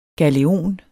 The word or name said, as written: galeon